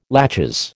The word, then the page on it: laches: American English